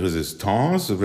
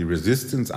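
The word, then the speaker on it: Résistance: Wenn er 35 Jahre alt ist, wird er die sogenannte sogenannte Resistance, wird französisch ausgesprochen, die Resistance anführen,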